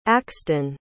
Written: axton

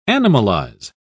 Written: animalize

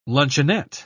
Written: luncheonette

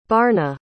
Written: barna

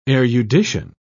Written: erudition

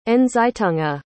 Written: Nzitunga

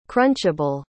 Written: crunchable

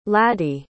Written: ladee

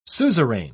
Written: suzerain

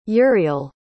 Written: uriel

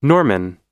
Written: Norman

